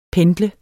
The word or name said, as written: Pendler